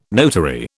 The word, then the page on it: notary: British English